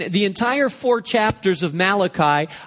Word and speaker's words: Malachi: Malachi, the entire four chapters of Malachi, by the way, it's not pronounced Malachi. It's Malachi, and he was a prophet,